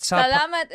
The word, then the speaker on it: salamat: Salamat. – Salamat is good.